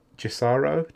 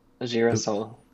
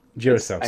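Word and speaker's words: Girassol: Is it Jisaro? Jisarsol? – Jirasol. – Jirasol, sorry.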